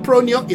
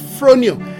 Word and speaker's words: phronio: It's spelled phronio, phronio. It's not pronio, it's phronio.